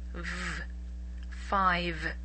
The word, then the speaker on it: five: So try to practice pronouncing the V. V. Five.